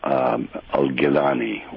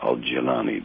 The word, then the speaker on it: Al-Jilani: And that's represented by one of the great savants of Sufism, Al-Gilani, or Al-Jilani, depending on your pronunciation.